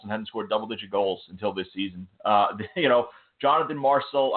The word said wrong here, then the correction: Marchessault: Marchessault.